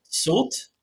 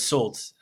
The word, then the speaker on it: Soult: But I think it's Soult with a T is pronounced. I have heard it other ways as well, but I'm going to go with Soult in terms of pronunciation.